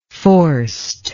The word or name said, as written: forcedness